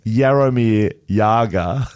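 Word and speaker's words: Jaromír Jágr: the great 8797, Jaromir Jagr, pronounced Jaromir Jagr, yep.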